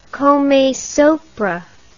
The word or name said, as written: come sopra